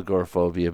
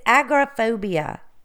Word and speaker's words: agoraphobia: And I'm not sure you're saying it correctly. It's agoraphobia. – I'm pretty sure it's agoraphobia. – I think you're putting the emphasis on the wrong word. You have the tendency to do that. – Are you telling me that I put the emphasis on the wrong part of the word? – You have the tendency to put the emphasis on the wrong syllable.